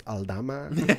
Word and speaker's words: Aldama: But sometimes I can be like, well, no, my last name is actually pronounced Aldama.